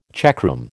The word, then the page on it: checkroom: American English